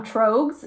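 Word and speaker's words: Tröegs: and it's Trogues. Is that how you say it? – Yeah.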